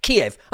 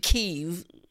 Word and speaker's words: Kyiv: It was always Kiev until recently, until this war started. Suddenly you have to pronounce it Kyiv. No, I'm an Englishman and I'll anglicise words and it says Kiev.